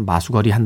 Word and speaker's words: 마수걸이: 발음은 마수거리인데, 네, 쓸 때는 마수걸이라고 어, 씁니다. 여기서 이제 마수가요. 그날의 장사 운수를 뜻하는 어, 단어라고 해요. 그래서 마수거리 한다.